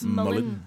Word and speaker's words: Molin: Men eh, jeg tror vi skal eh, få høre saken du hadde om Harold Shipman, og så den om Herbert Molin, er det slik det uttales? Mullin. Mullin, ja. Og så går vi videre til å snakke om staten som seriemorder.